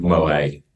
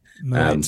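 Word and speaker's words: Moët: It's not Moet. Moet. So I'd actually get the pronunciation of the name right first.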